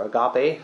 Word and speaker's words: agape: is agape, or agape, as many people pronounce it.